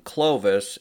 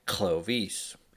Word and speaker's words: Clovis: Well, first, the English way of pronouncing Clovis is probably not the original pronunciation, which was probably pronounced more like Clovis, with the cl coming out as one sound, while the s at the end of the name was very soft.